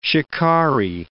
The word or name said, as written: shikari